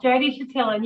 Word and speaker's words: Jody Chatillon: That's close enough, Jody Chatillon.